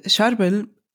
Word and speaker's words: Charbel: Wie wird der richtig ausgesprochen? – Charbel. Ach so, ja. Also der heilige Charbel.